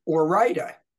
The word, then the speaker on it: Ore-Ida: And Orida, for those who are not otherwise familiar with the company, it's spelled O-R-E-I-D-A, which is another not official stumper question.